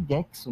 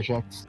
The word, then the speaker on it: Gex: Toda vez que eu, vejo, toda vez que eu via aquela propaganda, falava aí, o, o, o, é Gex ou Jex? Acho que é Gex o nome, não é Jex. Eu sempre li Jex.